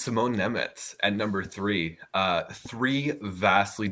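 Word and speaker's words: Nemec: And Brad, you are the only other person that I've heard pronounce it right. It is Simone Nemets.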